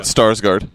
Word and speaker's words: Skarsgård: So there's always a Starsguard. The Skarsgård. – He was awesome. – Yeah, Stellan. – You said it right. Skarsgård. – Yeah, correct.